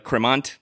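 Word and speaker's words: Crémant: Cremant is spelled with a T at the end, like Cremant, but, you know, one of the rules, or not a rule, but it's always nice, like if you go out to a restaurant, you know, and you're trying to learn about wine a little bit, try to figure out how to pronounce certain wines, you know, you just feel like less of an asshole when you do it. So this is pronounced Cremant, and it's essentially...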